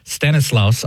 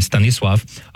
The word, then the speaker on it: Stanisław: And he's asking, who can pronounce Stanislaus, although in Polish it would be Stanisław, of Szczepanów, which is one of his titles.